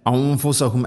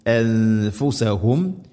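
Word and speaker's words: أنفسهم: അംഫു അല്ല അംഫുസഹും അല്ല അൻഫുസഹും യസ്ലിമൂൻ.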